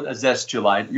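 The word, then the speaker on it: Azeztulite: You refer to a stone, I think it's called azestulite. Maybe I'm mispronouncing it. – I call it azestulite. You're pretty close.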